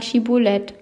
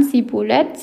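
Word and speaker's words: Schibboleth: Antworteten der Mann Nein, forderten sie ihn auf, sag einmal Schibolet. Wenn er dann Sibulet sagte, weil er das Wort nicht richtig aussprechen konnte, packten sie ihn und brachten ihn an den Furten des Jordan um.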